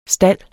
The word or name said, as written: STALDENES